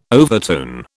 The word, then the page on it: overtone: British English